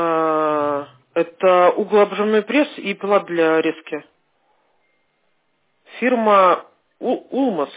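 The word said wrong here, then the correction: Yılmaz: или как она правильно читается? Илмас? – Илмас. – Илмас. – Илмас. – Вот. Буду знать.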